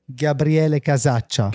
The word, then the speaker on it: Gabriele Casaccia: Gabriele Casaccia.